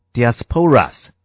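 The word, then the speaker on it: diasporas (διασπορᾶς): 第一个字就是分散那个字，分散这个字的希腊文读作 diasporas，这个字它是从两个字结合而来的。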